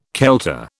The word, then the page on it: kelter: British English